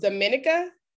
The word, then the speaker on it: Dominica: Of Dominica.